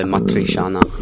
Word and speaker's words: matriciana: am I pronouncing it right? Matrishana? – No. M-A-T-R-I-C-I-A-N-A. – Oh, so matrishana.